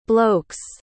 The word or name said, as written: bloke's